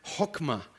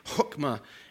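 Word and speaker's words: chokmah (חכמה): But the Hebrew word is chokmah. And it's almost like the way it's pronounced, it's almost like Klingon speaking. They got that really hard, kind of clearing the throat sound at the beginning, chokmah.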